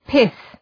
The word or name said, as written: pith